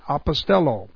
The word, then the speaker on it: apostello: There is pimpo and there's apostello. This one is apostello. Apostello emphasizes the relationship. Here's Apostello. I put Apo Stello here. Apostello, A-P-O-S-T-E-L-L, long O. This word, and they're both translated the same. This is Pempo, P-E-M-P-O.